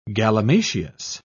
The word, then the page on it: galimatias: British English